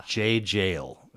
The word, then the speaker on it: Jaye Jayle: Jaye Jayle.